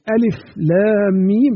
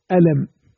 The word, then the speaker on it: الم: هذه الحروف المقطعة التي افتتحت بها السور كما لا يخفى إنها حروف عربية تقرأ بأسمائها في التهجي كما قلنا على التقطيع والفصل، يعني ألف لام ميم تنطق ألف لام ميم، لا يصح أن تقول ألم، لا، انتقلت إلى معنى آخر وقلبت المعنى رأسا على عقب وبدلت السورة وحرفت آياته.